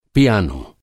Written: पियानो

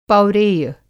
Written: पौरेय

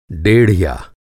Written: डेढ़िया

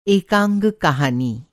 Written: एकांग

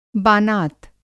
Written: बानात

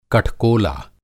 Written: कठकोला

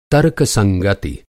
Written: तर्कसंगति

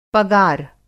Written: पगार

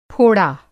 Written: फोड़ा

